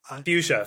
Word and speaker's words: Fuchsia: Fuchsia.